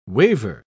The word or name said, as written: waiver